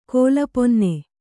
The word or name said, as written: ಕೋಲಪೊನ್ನೆ